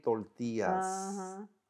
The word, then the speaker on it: tortillas: My mother every day would make homemade tortillas. Homemade flour tortillas. You got to say it like a Latino.